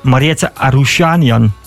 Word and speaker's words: Mariette Arushanian: En natuurlijk op 12 juli, ik hoop dat ik het uitspreek, Mariette Arushanian. – Volgens mij spreek je dat goed uit.